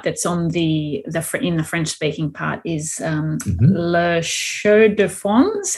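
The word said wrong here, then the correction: La Chaux-de-Fonds: La Chaux de Fonds, yeah.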